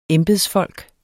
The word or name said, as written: embedsfolk